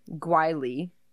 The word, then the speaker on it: Guile: How do you spell it? – G-U-L-E. – Oh. – G-U-I-L-E, sorry. – Gwheel. – Wheel. – It's really bad Guile.